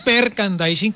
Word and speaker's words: merchandising: No, hay una mala pronunciación. Es merchandising, ¿correcto? Sí, ahí se...